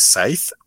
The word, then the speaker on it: scythe: Que es Scythe o sid no sé cómo se pronuncia. – Eh, scythe, sí, como el Pokémon de Scyther, ajá.